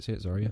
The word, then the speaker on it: Zarya: Is that how you say it? Zarya.